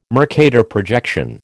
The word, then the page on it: Mercator projection: American English